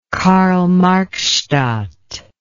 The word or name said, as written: karl marx stadt